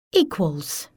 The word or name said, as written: equals